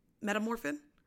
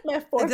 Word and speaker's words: Metformin: Like, did it really change the probability of getting pregnant that much to get on this Metamorphin? – Metformin.